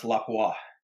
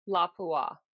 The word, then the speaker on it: Lapua: and Lapua. It's actually pronounced Lapua. – Lapua. Is that for real? – Lapua, yeah. That's for real. Lapua. Because it's a Finnish word.